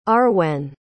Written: Arwen

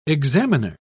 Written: examiner

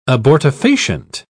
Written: abortifacient

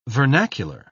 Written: vernacular

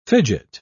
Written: fidget

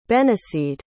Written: benniseed

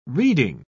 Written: reading

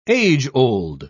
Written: age-old

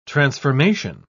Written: transformation

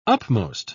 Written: upmost

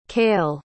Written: cael

